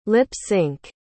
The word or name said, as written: lip-synch